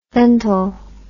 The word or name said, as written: ventil